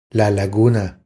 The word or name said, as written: La laguna